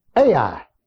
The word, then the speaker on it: Ai: He moved to Ai, as it's pronounced.